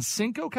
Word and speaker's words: Encino: In Encino, California, I think is how you pronounce it. – Encino? – That one.